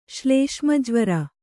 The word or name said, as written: ಶ್ಲೇಷ್ಮಜ್ವರ